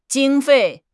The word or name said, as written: 经费